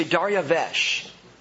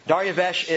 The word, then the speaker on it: Dariavesh: The Hebrew is actually Dariavesh. Dariavesh is probably closer to how you might pronounce it.